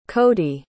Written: codi

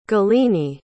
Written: galini

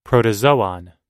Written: protozoon

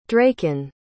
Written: dracon

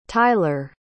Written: tylor

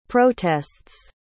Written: protests

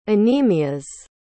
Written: anemias

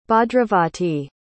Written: Bhadravati

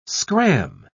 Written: scram

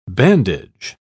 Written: bandage

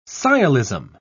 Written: sciolism

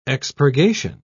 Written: expurgation

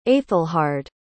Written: aethelhard

